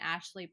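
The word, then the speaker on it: Ashly: My personal handle is at Lauren Ashley Perkins.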